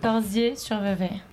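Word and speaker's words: Corsier-sur-Vevey: Le 12 septembre, est-ce que je prononce bien, vous serez à Corsière sur Vevey. – Presque. Corsier sur Vevey. – C'est juste Corsier sur Vevey en Suisse.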